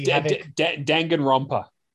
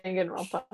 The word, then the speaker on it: Danganronpa: How the hell is a Dragonopia trigger happy? – Danganronpa. – Okay. – Danganronpa.